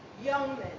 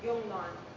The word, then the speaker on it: Jungmann: So, beginning with, to start our conversation about the history of the liturgy, I want to introduce you to Joseph Youngman. Now, my husband is German. I'm Italian and English. And he always says I pronounce his name totally wrong, that it's more like Jungmann.